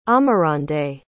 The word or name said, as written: amarande